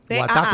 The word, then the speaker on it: Watta: Wata se escribe WATTA. – Guata como agua. – Como agua, guata.